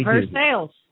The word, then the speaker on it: Versailles: Something we Versailles.